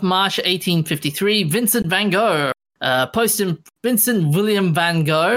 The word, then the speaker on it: Van Gogh: Yeah, it sounds more like cough in Dutch than it does to say Van Gogh.